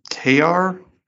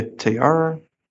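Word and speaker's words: Tár: Next, I think it's pronounced Tar, because it has the little accent, T-A-R, with the apostrophe on it, T-R.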